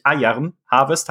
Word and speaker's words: Iron Harvest: Ja, schön, dass du da bist und äh, bitte an alle, die uns äh, zuhören, seht's mir in den Kommentaren nach, wenn ich hin und wieder Iron doch wieder falsch ausspreche und Iron Harvest sage. Es ist, es ist wirklich äh, schwierig. Iron Harvest heißt es.